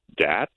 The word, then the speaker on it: Datz: I live pretty close to a place called Dats, which is, it's spelled D-A-T-Z.